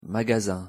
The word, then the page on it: magasin: French